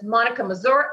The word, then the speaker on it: Monika Mazur: As you see, my name is spelled with K. So I'm M-O-N-I-K-A. And through that, you'll find it all. I have a personal Instagram that's Monica Mazur, M-O-N-I-K-A-M-A-Z-U-R.